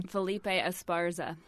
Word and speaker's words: Felipe Esparza: Felipe Esparza.